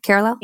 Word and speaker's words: Kerala: Kerala.